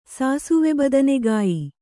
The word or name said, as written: ಸಾಸುವೆಬದನೆಗಾಯಿ